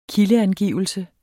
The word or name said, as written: kildeangivelse